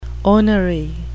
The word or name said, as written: ornery